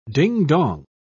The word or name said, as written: ding-dong